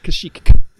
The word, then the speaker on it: Kashyyyk: Yeah, like Kashyyyk. There's two K's. You should pronounce them both. George Lucas does.